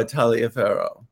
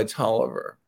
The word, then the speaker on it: Taliaferro: Well, if we were in Europe or South America, I do go by Taliaferro, but in the United States, I go by Tolliver. – So okay. – It rhymes with Oliver, and it's a Virginia family.